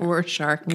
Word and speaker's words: Rorschach: How do you say that? – Rorschach.